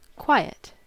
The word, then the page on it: quiet: /ˈkwaɪ.ət/